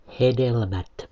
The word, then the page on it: hedelmät: /ˈhedelmæt/